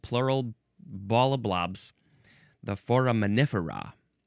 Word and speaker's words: foraminifera: So that was foraminifer. This is foraminifera. All we did was add an A to the end, and it completely changed the emphasis of how to say this word. Foraminifera.